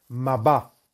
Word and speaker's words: Mabá: Las letras Mem, Bet, Hei, que se pronuncian Mabá,